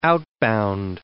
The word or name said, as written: outbound